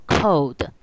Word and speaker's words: KODE: 好，那接下来哦，就是我们看到说它的题目里面有一个 KODE，它其实也是念作 code。